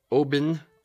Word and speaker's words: Oban: It's O-B-A-N is actually the spelling of it, but it's pronounced O-H, like emphasis on the O, bin, like a bin of trash Oban.